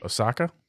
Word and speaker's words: Osaka: S-O-A-K-A? – O-S-A-K-A. – Osaka? – Osaka.